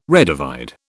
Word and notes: redivide: British English

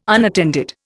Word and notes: unattended: Indian

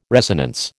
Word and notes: resonance: American English

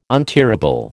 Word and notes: untearable: American English

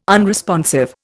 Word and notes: unresponsive: Indian

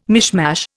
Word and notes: mishmash: Indian

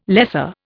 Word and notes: lesser: Indian

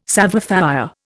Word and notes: savoir faire: Indian

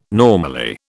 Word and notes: normally: British English

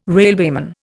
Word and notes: railwayman: Indian

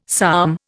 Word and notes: psalm: Indian

